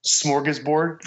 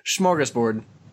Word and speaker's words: smorgasbord: Not smorgasboarding, just Nick. – Smorgasbord. – You say it like there's a CH at the front. – That's how all of the old Swedish people here say it.